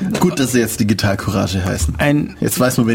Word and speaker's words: Courage: Ganz wichtig, Deutsch aussprechen. – Ja, genau. Also wobei Courage, ja doch. – Das ja, ist die deutsche Aussprache. Okay. Französisch wäre Courage.